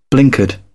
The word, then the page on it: blinkered: British English